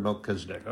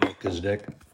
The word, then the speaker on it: Melchizedek: Okay, some people might want to pronounce it Melchizedek, but you have to remember that the Hebrew pronunciation is a little different than what ours is. So CH is more like a kind of what you would call a soft K sound.